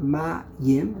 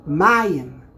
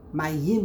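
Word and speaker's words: mayim (מים): The word ma yim is two syllables. Where do you put the syllable? Also milel. Mayim. It's not mayim. Mayim would be milara. That's incorrect. It's mayim underneath the mem, the second to last syllable, which happens to be the first syllable of the word.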